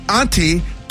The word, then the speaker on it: Antetokounmpo: Did I say it right? – Trey, did he say it? – I heard it. – Let me see. Giannis Antetokounmpo. Tacumpo. – There you go.